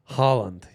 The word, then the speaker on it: Haaland: It's like you're choking. Yeah. Haaland. – Haaland. – Haaland. – Like the bread, challah. Challah. – Challah bread, yeah. Or challah. – Haaland, his dad played for Leeds.